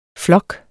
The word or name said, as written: flok